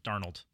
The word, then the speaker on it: Darnold: Darnold, right? Darnold. Darnold. All right, I said it right.